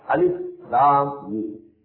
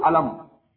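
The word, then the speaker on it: Alif Lam Mim (الم): Because they are pronounced separately. Alif Lam Mim. You can't pronounce it alam. You can't join them together. Although they are written together. Alif, Lam, Mim. Lam and Mim. They are joined together. But you can't read them joined together.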